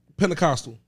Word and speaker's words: Pentecostal: We went to, I'm a name drop the type of Pentecostal.